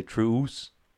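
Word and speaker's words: Truus: You know, Truce is T-R-U-U-S. I think you're actually supposed to say Truce. Like, you pronounce both U's.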